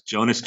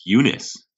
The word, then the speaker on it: Jonas: Like, so I always thought it was Jonas Jerebko. I heard season that it's Eunice.